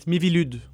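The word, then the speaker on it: MIVILUDES: So the French newspaper Le Figaro reported that the French agency tasked with tracking sectarian movements, which goes by the acronym Mivoldes, how do you pronounce that? – It's Mivilud.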